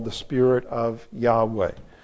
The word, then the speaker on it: Yahweh: But several generations ago, scholars took that apart and said, it's probably better pronounced Yahweh. That's what most people pronounce it today, but we cannot say for sure that that's how it's pronounced.